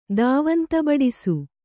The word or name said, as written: ದಾವಂತಬಡಿಸು